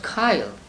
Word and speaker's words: chyle: See, H-Y-L-E, it is pronounced Kyle. Please look it up in the dictionary.